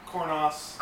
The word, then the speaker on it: Cornas: I still like the San Reckoner best, but the Cornal, is that how you say it? – Cornas. It should be Cornal because France, but again, I saw an interview with the vintner, and he called it, the native Frenchman, he called it Cornas.